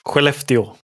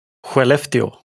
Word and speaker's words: Skellefteå: Skellefteå. – Yeah. So for our listeners, I'll just explain to you. So I'll say it again. What's it called? – Skellefteå.